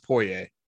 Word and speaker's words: Poitier: But, and then on top of all that, like, also Sydney, I'm having trouble pronouncing that. Sydney Poitier? – Poitier. I think it's called pronounced Poitier.